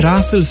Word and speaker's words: Russell: Ime Russell se sklanja podobno. Russell, rasla, raslu, rasla, obraslu z raslom. V tem primeru je E pred soglasnikom pravzaprav polglasnik, ki pri sklanjanju izgine.